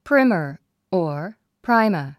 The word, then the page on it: primer: prímə r; プライマァ